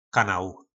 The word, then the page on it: kanal: po našimu